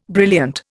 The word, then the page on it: brilliant: Indian